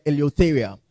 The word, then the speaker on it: eleutheria: Now, freedom comes from the Greek word eleutheria. Eleutheria is spelled E-L-E-U-T-H-E-R-I-A. Eleutheria. E-L-E-U-T-H-E-R-I-A.